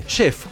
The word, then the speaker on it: chef: Chef. ¿Cómo se dice? Chef. – ¿Y qué estás diciendo? Pero los chef dicen chef. – Lo dice en francés.